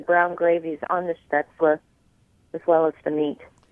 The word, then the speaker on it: Spätzle: So what is Spätzle, and did I mispronounce that? – Spätzle. It's they're a Bavarian egg noodle.